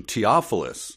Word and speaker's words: Theophilus: The correct pronunciation, the biblical pronunciation, Theophilus.